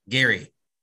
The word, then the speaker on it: Gerry: His name is pronounced the same as the quarterback from Remember the Titans, which is Gerry.